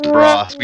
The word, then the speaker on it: DeBrosse: It's DeBrosse. We Americanized it. – DeBrosse. You dropped the long E. – We dropped the Frenchism on it.